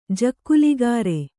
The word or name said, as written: ಜಕ್ಕುಲಿಗಾರೆ